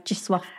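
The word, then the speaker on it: Zdzisław: You said the spelling of Czesław Patrzynski. – Z-D-Z-I-S-L-A-W. – Okay. So how I've got it written down is J-I-S-W-U-F.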